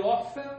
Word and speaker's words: often: I often go online because I needed to send messages. – Send message. Or I often, right? That's not a typical pronunciation. You don't pronounce the T, right? It's often.